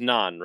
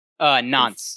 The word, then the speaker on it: Nantes: I don't think they pronounce the T in the T-E-S, so I think it's pronounced Nantes.